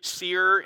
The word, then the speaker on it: Seir: If you read seer in the Bible, S-E-I-R, like it's spelled here, it's talking about Edom, but it's also talking about their practice of divination and different things.